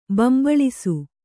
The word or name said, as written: ಬೊಂಬಾಳಿಸು